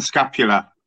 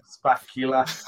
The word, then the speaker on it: scapula: Yeah, so count scapula. – Way! time, Spacula.